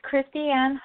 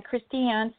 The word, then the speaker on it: Christiane: It's Christiane Heil. And my last name is spelled H-I-L-E. So again, Christiane, C-H-R-I-S-T-I-A-N-E. And last name is Heil, H-I-L-E.